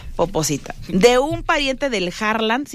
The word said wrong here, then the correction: Holland: ¿sí se llama Harland? Yo siempre le digo Harland. – Holland. – Ah, se pronuncia Holland.